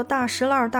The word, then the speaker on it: 大栅栏: 而且，大栅栏的读音呢也特别，民间口耳相传的读作大石栏、大石栏，是融合了少数民族方言的北京土话。还有关于大石栏的一首民谣：“ 大石栏里买卖全，绸缎烟铺和戏院，药铺针线鞋帽店，车马行人如水淹。可见当时的繁华。